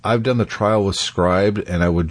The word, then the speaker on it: Scribd: Scribd. I hate how people do that. It's pronounced this way, but we spell it that way.